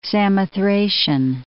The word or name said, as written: Samothracian